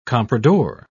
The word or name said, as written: comprador